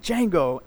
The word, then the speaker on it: Django: The D is silent, Django, and he's on a horse.